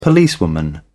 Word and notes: policewoman: British English

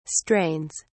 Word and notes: strains: British English

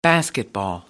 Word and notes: basketball: American English

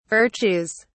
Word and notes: virtues: British English

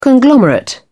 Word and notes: conglomerate: British English

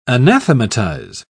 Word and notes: anathematize: British English

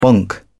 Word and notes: bonk: British English

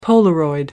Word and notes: polaroid: American English

